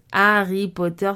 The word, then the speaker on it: Harry Potter: Beaucoup de personnes adorent lire Harry Potter. Oui, je prononce Harry Potter sans prononcer le H.